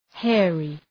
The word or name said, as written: hairy